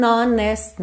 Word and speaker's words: honest: Essa palavra honest tem uma curiosidade, que esse H é mudo, o que não é comum em inglês, certo?